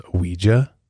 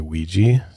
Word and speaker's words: Ouija: It's like technically pronounced Ouija. – Ouija? – Yeah, mm-hmm. but over time, we just say Ouija, so.